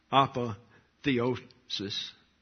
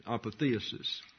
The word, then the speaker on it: apotheosis: I want to sort of amplify this idea of apotheosis actually is the way it's pronounced in the Greek, and I think that's the English pronunciation, apotheosis.